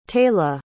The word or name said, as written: taylah